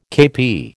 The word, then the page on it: kepi: American English